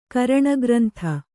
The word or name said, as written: ಕರಣಗ್ರಂಥ